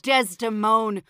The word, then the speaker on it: Desdemona: Oh, Desdemone. Away, away, away. – Good. And so he doesn't have the last syllable of her name, so it's almost like a dear thing, right?